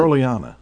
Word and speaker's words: Petroliana: Petrolania, or what is it? – Petroliana. – Petroliana. – Yeah. – I knew I'd mispronounce it.